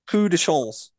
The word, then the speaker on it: Coup de chance: Coup de chance.